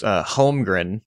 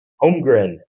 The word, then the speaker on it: Holmgren: I know it's Holmgren. – Holmgren. – Yeah, the L just doesn't exist whenever you're pronouncing it. – Holmgren.